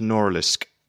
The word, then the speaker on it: Norilsk: And, I mean, the town where you're from, I think it's pronounced Norilsk.